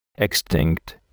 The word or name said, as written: extinct